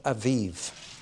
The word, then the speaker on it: Aviv: It's sung on the particular date, the 9th of Aviv, A-B-I-B, but it's pronounced like a V.